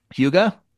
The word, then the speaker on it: Hygge: I have two packets of, tell me how to pronounce H-Y-G-G-E. – Hygge? – Hygge.